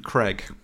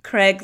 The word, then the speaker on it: Craig: Craig and Craig. – Craig is what I should say. Craig, like an egg. – Craig to rhyme with Greg. No, Craig to rhyme with bagel.